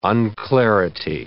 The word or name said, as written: unclarity